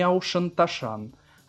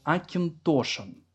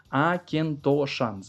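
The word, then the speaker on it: Auchentoshan: Не очень Тошин, не Аушенташан, Акинтошин. У них, кстати, мастер-классы, когда начинаются у Акинтошин, они начинаются с того, что включают видео, в котором разные люди правильно произносят название винокурни и название виски. Акентошан, запоминайте.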